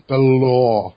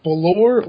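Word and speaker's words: Bálor: They announced Rollins, Bryan, Ambrose, Barrett, Paige, and Finn Balor. Is it Balor or Baylor? – It's Baylor. – Because I heard it pronounced like 90 ways. – It's Balor.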